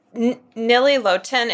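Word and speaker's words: Nili Lotan: Wide, and I might just get them because I want – there's Nili Lotin. Am I saying her name right? You know I love her. – Yes. – You are. Yes.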